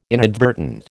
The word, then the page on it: inadvertent: American English